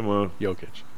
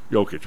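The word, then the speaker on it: Jokić: Jokic. – Jokic. Whatever. I added a syllable.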